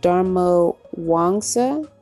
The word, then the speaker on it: Darmawangsa: She's a cool millennial on YouTube who's basically talking about how to succeed in online business, which is Jade Dharma Wangsa. Wow, it's exactly the way that it's spelled. Jade D A R M A W A N G S A.